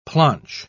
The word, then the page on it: planch: British English